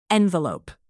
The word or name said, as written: envelope